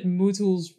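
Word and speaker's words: MooTools: Yeah, it's funny that moo tools rhymes with new tools, right? – That's ironic.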